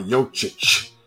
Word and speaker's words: Jokic: Okay, it's a ch on the end of it. There's no H on it, but you have to pronounce the ch. So Nikola Jokic.